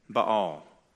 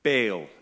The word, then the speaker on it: Baal: Now, we're going to discuss him by name in just a few more verses, but it's worth spending some time here addressing Baal. Baal, you could say, if I want to save a syllable.